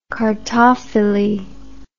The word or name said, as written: cartophilist